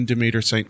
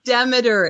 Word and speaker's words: Demeter: Demeter. It's Susan Demeter-St. Clair. Demeter as like the goddess. – The Greek goddess.